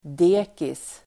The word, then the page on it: dekis: d'e:kis